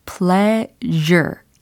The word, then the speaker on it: pleasure: 소확행에 해당하는 표현으로 알려드린 거는 simple 다음에 pleasure 이라고 발음이 됐습니다. 철자도 살짝 달랐죠. p-l-e-a-s-u-r-e, e, pleasure, pleasure 이 발음이었어요.